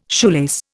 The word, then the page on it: shoelace: Indian